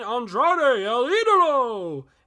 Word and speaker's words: Andrade El Idolo: He called him Andre L I L Andre and you had you had Excalibur like um JR, you mean Andrade El Idolo?